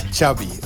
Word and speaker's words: Xavi: Xavi. – Ja. Okay. Yeah. So Xavi like chocolate. – Yes. Okay. Thank you very much. – Nou, dat is tenminste duidelijk, toch? Yeah. Straks meer over die Xavi.